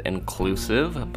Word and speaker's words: INCLSV: and the name of it is called Inclusive, but it's spelled INCLSV.